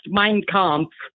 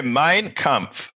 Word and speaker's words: Mein Kampf: Okay, first it's pronounced Mein Kampf. – How do you say it? – Hitler's book. Mine? – Mine? – Like a landmine. – Oh, okay. Mein Kampf.